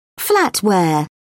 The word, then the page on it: flatware: British English